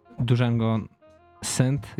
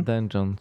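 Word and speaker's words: dungeon synth: Elektronikę, ambient i ten Django synth, jeżeli dobrze to wymawiam. – Dungeon.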